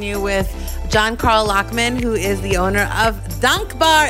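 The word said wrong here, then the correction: Dankbaar: It's like a longer A. Dunk Bar.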